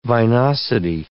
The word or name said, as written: vinosity